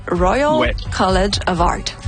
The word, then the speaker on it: Royal College of Art: Et là, c'est le moment où je vais essayer de le prononcer. C'est le Royal College of Art. – C'est ça? – Bon. Oui, c'était bien ça.